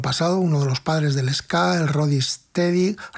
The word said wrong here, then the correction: Rocksteady: Rocksteady, que lo digo mal, y bueno, el reggae, ¿no?